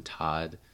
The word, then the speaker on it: Todd: Todd um, it's T O D D and Noah's spelled like it sounds, and the M is just my middle name.